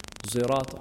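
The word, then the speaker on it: صراط: they pronounce sirat al-ladhina an'amta alayhim as a cross between a sad and a zai. Sirat al-ladhina an'amta alayhim, or alayhum, or in this case zirat, or zirat al-ladhina an'amta alayhum.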